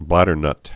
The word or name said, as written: bladdernuts'